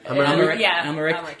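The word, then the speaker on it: Amalric: You have the other servant who's played by Matthew, how do you pronounce it? – Amaric, whose name is...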